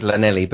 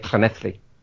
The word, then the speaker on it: Llanelli: Double L in Welsh is a cl. So, yeah, so there's a town in Wales that's double L-A-N-E-double-L-I. It's, phonetically, it's Llanelli, but they say it Clanethly.